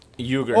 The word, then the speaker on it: Uyghur: Uyghur. – Or I think they're pronounced... – Uyghur. – Uyghur, yeah. – Yeah, you're right. – Uyghur. It's Uyghur, yeah. – Yeah. It has a W sound for whatever reason.